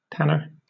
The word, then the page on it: tanner: British English